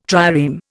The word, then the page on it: trireme: Indian